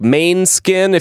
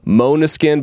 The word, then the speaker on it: Måneskin: It looks like Maine skin. If you're American, if you're European, you probably pronounce it Måneskin. I learned that the word is Danish and is properly pronounced Måneskin.